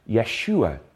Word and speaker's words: Yeshua: So in the Old Testament, they pronounced Jesus as Yeshua, Joshua, we pronounce it in English.